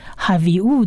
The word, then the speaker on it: Havi'u: 那这个辅的音呢加上下面的这一个点跟左上方的一撇是长母音 e 的音，所以是 v havi'u 的 v 的音。再来第三个字母呢是希伯来文字母的 Aleph 呢是末音字母，它不发音。那不，它不发音呢，它左边的这一个所谓的母音字母 U 的音，它要发音，所以就变成 Havi'u 的 U 的音。